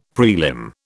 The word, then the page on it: prelim: British English